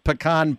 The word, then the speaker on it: pecan: All right, in the book, I think that there is a recipe for a coconut pecan pie. Now, you tell me, how do you pronounce P-E-C-A-N? – I pronounce it, David, the way it's spelled, pecan. – You know, is that right? – Well, yes. – It's not P-E-C-O-N.